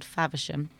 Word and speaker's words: Faversham: I grew up in Canterbury and it was actually a town called Faversham, which is 10 miles away from Canterbury.